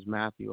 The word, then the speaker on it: Matthew: My name is Matthew Armand.